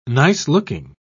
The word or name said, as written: nice-looking